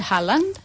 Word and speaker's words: Haaland: Recoy, gràcies, es pronuncia Haaland, sí.